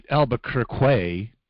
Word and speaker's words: Albuquerque: If you say it right, Albuquerque.